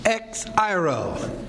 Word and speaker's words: exairo: And it is exairo. E-X-A-I-R-O. E-X. Sometimes pronounced K-S. X. Okay? And then A-I-R-O.